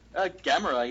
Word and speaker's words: Gamera: Do you feel like, am I saying it wrong? Is it not Gamera? – Gamera, yeah.